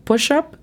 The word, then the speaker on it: push up: Bueno, push-up significa lagartija o flexión. Y la pronunciación fonética es P-U-S-H-A-P.